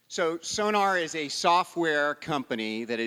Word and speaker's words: Sonr: Sonr? Sonr.